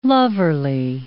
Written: loverly

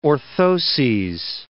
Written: orthoses

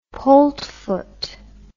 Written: poltfoot

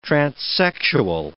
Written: transsexual

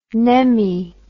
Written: nemmie

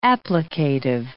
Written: applicative

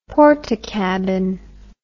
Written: portakabin

